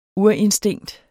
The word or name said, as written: urinstinkt